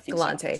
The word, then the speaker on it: Galante: James Galante Galante am I saying it right Galante yeah Galante so.